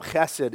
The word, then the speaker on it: chesed: It's the Hebrew word chesed, which you can't say correctly without hawking a little something up. Chesed is how you say it.